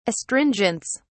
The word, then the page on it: astringents: British English